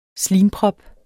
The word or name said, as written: slimprop